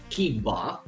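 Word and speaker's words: Kimber: Ma William Billy Kimba, come lo pronunciano loro, non sono in grado, però mi faceva ridere.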